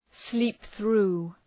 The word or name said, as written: sleep through